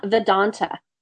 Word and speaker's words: Vedanta: You are certified as a hatha yoga instructor, and you've also studied Vedanta. Am I saying that correctly? – Vedanta.